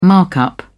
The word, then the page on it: markup: British English